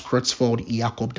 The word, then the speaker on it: Creutzfeldt-Jakob: That's Creutzfeldt-Jakob disease, right? Creutzfeldt-Jakob disease, that's spelled as C-R-E-U-T-Z. F-E-L-D-T, right? Creutzfeldt-Jakob, some people say J-A-C-O-B or J-A-K-O-B, right?